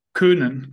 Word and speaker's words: Köhnen: Du mir zuschicken und dann dein Nachname, spricht man den Kohnen aus oder Köhnen? – Köhnen, genau. – Köhnen. – Genau. – Also nicht wie Coesfeld.